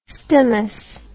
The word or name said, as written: stomous